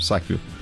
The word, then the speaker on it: Sackville: Bom, meu sobrenome é Cauã Sackville. Então, meu avô sempre falou que se lia com o som de Z, mas a gente lê como se escreve mesmo, Sackville.